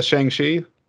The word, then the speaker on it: Shang-Chi: Nah, did you see Shang-Chi? – I did.